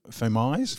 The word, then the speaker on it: Fomies: This band, now I don't even know if this how you pronounce it, Fomies, F-O-M-I-E-S. Fomies? – That's how I would say it too. – M-O-U-S-E. Yeah. Fomies or Fomies? – Fomies, yes.